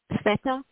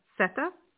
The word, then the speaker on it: Sweta: Sweta. – Okay, I'm not hearing it properly. Can you spell it for me? – Mm-hmm. Sure, S-W-E-T-A. – Sita. Sweta or Sita? Sweta? – Sweta, yes.